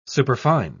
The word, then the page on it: superfine: British English